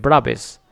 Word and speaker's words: Braves: Pero no se escribe Bravos, se escribe Braves.